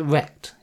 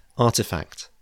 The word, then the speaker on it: RTFKT: given Nike's position in all of this, acquiring RTFKT, do you pronounce it rect? Is that how I meant to say it? Maybe not. – Sorry. Yeah, artifact.